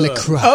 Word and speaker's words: LaCroix: LaCroix.